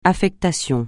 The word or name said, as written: affectation